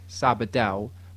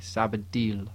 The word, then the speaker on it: Sabadell: Sabadell, is that right? I say Sabadell, they say Sabadell. Yeah, and I was like, that's what I'm saying, Sabadell. And they go, no, Sabadell.